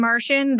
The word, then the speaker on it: Marsian: Mostly on Instagram at Captain Martian.